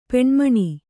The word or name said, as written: ಪೆಣ್ಮಣಿ